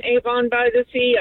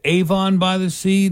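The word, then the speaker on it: Avon-by-the-Sea: I know Judy may already know this, but it is pronounced Avon by the Sea, not Avon by the Sea? – Well, the new people say Avon because it's more hoity-toity.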